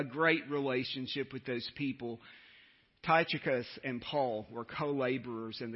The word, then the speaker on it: Tychicus: Tychicus is how you pronounce that name.